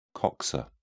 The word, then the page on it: coxa: British English